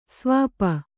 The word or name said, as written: ಸ್ವಾಪ